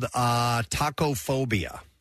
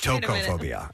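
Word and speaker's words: tokophobia: This fear is called tacophobia. – Okay. – Which is spelled T-O-K-O phobia, not T-A-C-O. Like, maybe it's tocophobia. I don't know.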